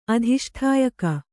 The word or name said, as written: ಅಧಿಷ್ಠಾಯಕ